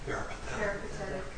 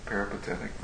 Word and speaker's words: peripatetic: Does anyone know how to pronounce it? – Peripatetic. – Peripatetic, thank you.